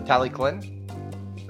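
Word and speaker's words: Talyllyn: The Tallyclen. That's actually the correct Welsh pronunciation. – Tallyclen.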